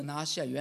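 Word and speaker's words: 拿细耳: 这拿西尔原来就是希伯来文的一个单词，它的读音就叫拿西尔。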